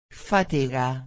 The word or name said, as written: fatiga